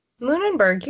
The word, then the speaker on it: Lunenburg: We're going to talk about, I still don't know how to say it, Lunenburg? – Lunenburg, you got it.